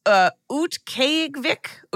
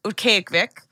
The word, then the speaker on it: Utqiaġvik: But it's Utqiaġvik. Utqiaġvik.